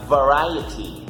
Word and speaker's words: variety: En inglés esta palabra se pronuncia variety. Variety, ¿ok? Porque sé que por ahí algunos tienen dificultades de cómo pronunciarla. Aquí les recuerdo. Variety.